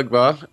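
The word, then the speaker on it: Ogbah: Emmanuel Ogba, am I saying that right? – Emmanuel Ogba.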